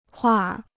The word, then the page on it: 画儿: huàr